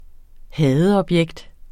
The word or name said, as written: hadeobjekt